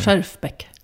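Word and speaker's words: Schjerfbeck: Du, senast vi såg så pratade vi om att du gärna ville köpa en Helene Scherfenbeck. Jag kan inte uttala det. – Scherfbeck. Ja. Helene Scherfbeck.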